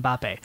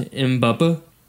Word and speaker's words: Mbappe: He has got half the amount of goals as Lily Gleader, Kylian Mbappe. – It's pronounced Mbappe. – Mbappe? Sorry, Mbappe.